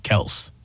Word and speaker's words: Kelce: I think, well, we say Kelce, but I think on the podcast, he said their last name is actually Kels.